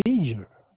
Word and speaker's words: V'Ger: The Veger. I may be pronouncing it wrong. It's V'GER. – Oh, Veger.